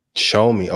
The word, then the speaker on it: Xiaomi: Xiaomi. Okay, gotcha. – Yeah. Yeah, show me, but it has an X. It's spelled with an X. – Yeah, X-I-A-O-M-I.